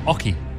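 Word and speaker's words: oche: It's pronounced Oki.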